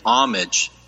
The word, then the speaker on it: homage: For we observed his star at its rising and have come to pay him homage. Because the H is silent.